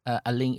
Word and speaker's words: Alun: Alin is a Welsh pronunciation. You'll see his name spelled as A-L-U-N.